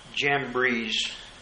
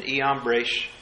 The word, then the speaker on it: Jambres: We pronounce them Jannes and Jambres, Iannes and Iambres.